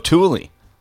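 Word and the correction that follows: tulle: No, you don't say the E. You just say tool.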